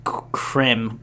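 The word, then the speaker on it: Crème: Cre'me. It's how it's supposed to pronounce it. Cre'me. – There's a little thing over the E, so we have to. – Accent it or emphasize it or whatever.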